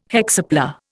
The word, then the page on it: hexapla: Indian